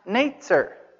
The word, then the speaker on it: netzer: In Hebrew, the word for branch is pronounced netzer. Do you see the connection between the word netzer and Nazarene?